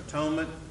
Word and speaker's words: atonement: Remember how he pronounced atonement? At one-ment. To atone for sin is to make you once again one with God.